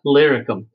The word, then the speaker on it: Illyricum: And this Illyricum, I guess I get it pronounced correctly, Illyricum.